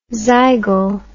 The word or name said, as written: zygal